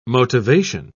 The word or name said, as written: motivation